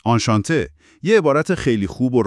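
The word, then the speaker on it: enchanté: اون ای آخرش توی نوشتار برای خانوم ها میاد، ولی تلفظش معمولا فرقی نمیکنه. – فهمیدم، آنشانته.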